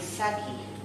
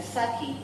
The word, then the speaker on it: Sackey: I'm Bridget Saki. Bridget is spelled B-R-I-G-I-D. So the E must be I, and then Saki, S-A-C-K-E-Y. Insert a C before the K. Bridget is B-R-I-G-I-D.